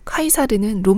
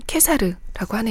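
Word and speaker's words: Caesar: 그 키케로, 로마 발음으로는 치체로이고요. 카이사르는 로마 발음으로는 케사르라고 하네요.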